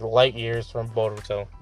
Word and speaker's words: Boruto: Like, Boruto.